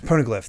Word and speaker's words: Poneglyph: She's just like, hey, take me to the Poneglyph. I don't even know how it's pronounced. – I think Poneglyph. – Poneglyph, that's it.